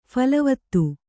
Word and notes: ಫಲವತ್ತು: phalavattu

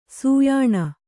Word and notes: ಸೂಯಾಣ: sūyāṇa

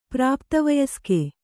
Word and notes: ಪ್ರಾಪ್ತವಯಸ್ಕ: prāpta vayaska

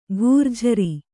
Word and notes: ಘೂರ್ಜರಿ: ghūrjari

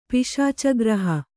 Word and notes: ಪಿಶಾಚಗ್ರಹ: Kannada

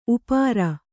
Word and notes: ಉಪ್ಪೇರಿ: uppēri

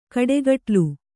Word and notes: ಕಾಡುಕಡಲೆ: kāḍu kaḍale

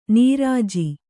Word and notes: ನಿರಜ: niraja

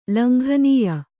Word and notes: ಲಂಘನೀಯ: langhanīya

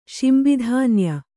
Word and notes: ಶಿಂಬಿಧಾನ್ಯ: śimbi dhānya

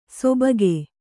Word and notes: ಸೊಬಗೆ: Kannada

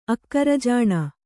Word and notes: ಅಕ್ಕರಜಾಣೆ: kannada

